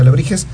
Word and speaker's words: Alebrixhez: Es, este, es una composición de una palabra, Alebriches, se pronuncia Alebrijes.